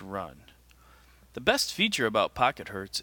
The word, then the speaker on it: Pocket Hz: I pronounce it Pocket Hertz, but it's spelled Pocket HZ.